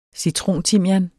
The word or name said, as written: citrontimian